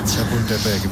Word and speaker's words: Chapultepec: Chapultepec. Mejor que algún cementerio francés. – ¿Díglese? Chapultepec, bien.